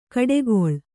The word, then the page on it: ಕಡೆಗೊಳ್: kaḍegoḷ